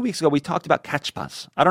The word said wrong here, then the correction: CAPTCHA: Catchphas? I think it's captcha. – Captcha. – Like it's capturing captcha.